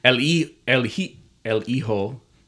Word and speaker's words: Hijo: However, at the beginning of the match, the announcer introduced him as El Hijo, because I think the H is not pronounced,